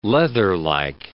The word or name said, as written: leatherlike